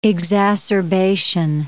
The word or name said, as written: exacerbation